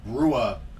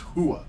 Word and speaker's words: Rua: So that's you know like for all those years I was calling the UFC fighter Mauricio Shogun Rua Rua. No, it's pronounced Hua. It's Shogun Hua because in Portuguese the R is an H.